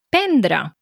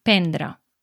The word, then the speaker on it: prendre: Doncs sí, és una pregunta interessant perquè és veritat que s'escriu que el verb conté dues R's, s'escriu prendre, però la primera R no es pronuncia normalment, o sigui, la major part de la gent no la pronuncia.